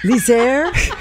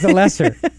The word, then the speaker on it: Isère: I thought it was the lesser because the way it's spelled, the French ship, the lesser, L-S-E-R-E. – Isere. – I literally thought it was the lesser.